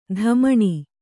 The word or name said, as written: ದಮ್ಮಣಿ